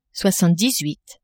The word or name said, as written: soixante-dix-huit